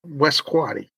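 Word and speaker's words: West Quoddy: Spell it again for me, Q-U-O-D-D-Y. Yeah, West Quaddy.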